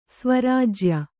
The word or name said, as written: ಸ್ವರಾಜ್ಯ